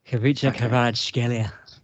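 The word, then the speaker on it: Khvicha Kvaratskhelia: Kavica Kvaratskhelia. Can I get a round of applause for that one? That was the... – you do, because that was actually in a really good pronunciation of the Georgian player.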